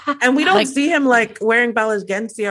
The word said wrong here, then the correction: Balenciaga: or whatever tennis shoes. I probably mispronounced it because I really don't know. – Balenciaga.